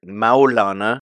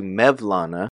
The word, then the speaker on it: Mevlana: So in Arabic, Maulana becomes Maulawi in a sort of adjectival sense. So a Maulawi is someone who follows the Maulana, and that became Turkified in pronunciation to Mevlana. So they are the Mevlana order.